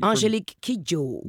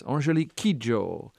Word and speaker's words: Angélique Kidjo: Angélique Kidjo. – Yes. Angélique Kidjo.